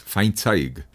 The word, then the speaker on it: Feinzaig: Entonces, es una pronunciación muy alemana. En realidad, eh, la pronunciación original es Feinzeig.